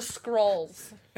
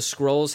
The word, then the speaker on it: Skrulls: The Skrulls. – Elder Scrolls. – No. – The Skrulls are the spelled like skull with an R.